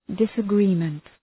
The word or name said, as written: disagreement